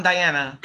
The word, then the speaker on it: Diana: I say my name is Diana, but when I'm talking in English, like, oh yeah, I'm Diana.